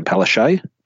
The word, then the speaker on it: Palaszczuk: Queensland, I don't know what deal they've done with Anastasia Palaszczuk, and I really only just wanted to get her name in there to show that I could pronounce really, it.